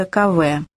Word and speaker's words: Каве: Оставив слева деревню Мадзамбе, мы за час с небольшим добрались до населенного пункта Каве, название которого произносится с ударением на последнем слоге.